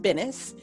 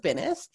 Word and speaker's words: Binis: So my name is Jenn, and actually it's pronounced Binis, which is totally unfair to everybody because it wasn't originally Binis.